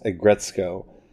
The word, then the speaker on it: Aggretsuko: I'm going to spell it for you just to make sure you can find it. It's A G G R E Ag Re Co. That's how it's spelled. It's pronounced Aggretsuko. So R, I'm sorry, A G G R E T S U K O. Aggretsuko.